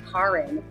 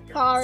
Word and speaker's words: Karin: So they put the I because that's the way the Norwegians would spell it. And they would actually pronounce it Karin. – Karin.